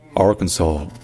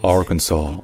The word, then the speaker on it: Arkansas: Que le S à la fin, il est prononcé dans Kansas, Kansas, Kansas, alors qu'il n'est pas prononcé dans Arkansas. C'est juste Arkansas, Arkansas.